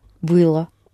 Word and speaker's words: было: Это было интересно. – Good, only don't accent the о. – Это было интересно.